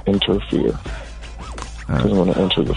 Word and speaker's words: Enterfear: It's called Interfere. It's crazy. We're just talking about fear. But it's one word, and it's spelled like E-N-T-E-R-F-E-A-R. – Oh, Interfere.